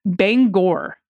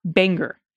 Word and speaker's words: Bangor: The name of the city in Maine where Susan Collins and Stephen King live is pronounced Bangor, not Banger. It's Bangor. All right, this is always a clue to Maine's.